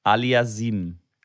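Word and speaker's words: Aliassime: Aliasim.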